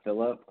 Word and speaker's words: Philip: I usually introduce myself by Philip. I say, why not deserve the extra syllable in my name? – There you go.